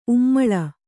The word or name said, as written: ಉಮ್ಮುಲು